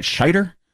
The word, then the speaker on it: Scheider: I always say his name wrong. It's not Schneider, right? Scheider? Is that how you supposed to pronounce that? Yeah, he's dead.